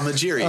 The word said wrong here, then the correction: Mejri: Or Mejri. Mejri, sorry. – Yeah, I think you butchered his name. – No, it's Salah Mejri.